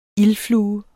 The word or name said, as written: ildflue